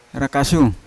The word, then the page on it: rakasyu: Yawa